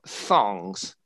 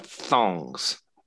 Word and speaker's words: thongs: So this th, we put our tongue between our teeth th, and make this right. th sound. So we have thongs.